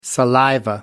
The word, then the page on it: saliva: American English